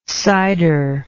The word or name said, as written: ciderlike